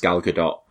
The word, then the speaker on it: Gal Gadot: So yeah, I mean, I think Gal Gadot's, and I'm also aware, I'm sure it's supposed to be pronounced Gal Gadot, but I'm going to slip in and out of that.